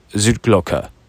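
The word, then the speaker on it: Zur Glocke: Uh, Zut Zutglocke as uh, Hannah, one of the girls I met at the at the bar, told me that's how you say it.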